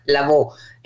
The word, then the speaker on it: Lavaud: Mi apellido se pronuncia Lavó.